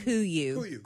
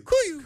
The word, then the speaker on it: KUIU: K-U-I-U. – It's say they came up with the name as a hunting call. It's a hunting call for like a bird or something. – It's pronounced Kuyu. – Kuyu.